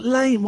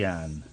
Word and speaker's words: Iain: It's not Lane.